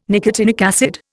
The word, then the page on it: nicotinic acid: Indian